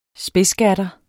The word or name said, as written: spidsgatter